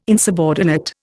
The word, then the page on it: insubordinate: Indian